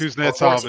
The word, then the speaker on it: Kuznetsov: Kuznetsov 14. is good. Kuznetsov, yeah. Thank you for pronouncing that right for me.